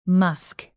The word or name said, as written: musk